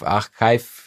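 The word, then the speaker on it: arXiv: Und da bin ich äh, die Journals durchgegangen in der Astronomie, da gibt es das sogenannte Archiv oder Archiv Xiv, Archive äh, ausgesprochen.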